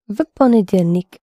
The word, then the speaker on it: в понедельник: So, понедельник is Monday, and on Monday is into Monday, which is в понедельник. – Yes, but when you pronounce it in the sentence, you can hardly hear the V because it precedes P. В понедельник.